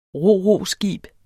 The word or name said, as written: ro-ro-skib